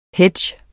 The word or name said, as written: hedge